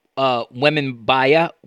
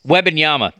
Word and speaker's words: Wembanyama: And you know what's fascinating is, I think, I mean, maybe I'm wrong about this, but like 15, 20 years ago, Victor Wembanyama would, and if I mispronounce his name, I apologize. – Wembanyama. – That I totally mispronounced.